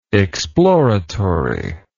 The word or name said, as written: exploratively